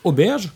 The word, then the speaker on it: Auberge: Et puis en 1990, Chris Rea va sortir un de ses chefs-d'œuvre, l'album Auberge, comme ça se prononce,